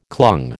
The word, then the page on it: clung: American English